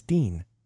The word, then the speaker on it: Dien: It's Dean. – Is it? – Yeah, Casper Van I thought Van it was Dien. D-I-E. – It is. – Oh, okay.